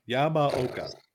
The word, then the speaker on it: Akira Yamaoka: Yamaoka.